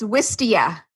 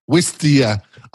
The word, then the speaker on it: Wistia: Whistler well, what's how you pronounce it? – Here, it's Wistia. – Wistia.